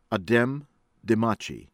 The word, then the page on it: Adem Demaci: from Albania